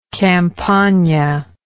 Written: campagna

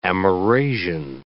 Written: Amerasian

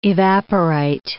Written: evaporite

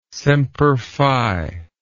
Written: semper fi

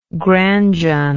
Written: Granjon

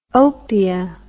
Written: opia

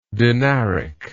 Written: dinaric

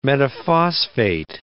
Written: metaphosphate

